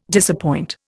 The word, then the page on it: disappoint: Indian